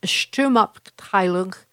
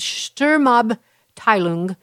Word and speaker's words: Sturmabteilung: the Sturmabteilung, I butchered that, but it's Sturmabteilung, to project power through intimidation, while blaming, that's the stormtroopers, by the way, blaming the communists for the violence.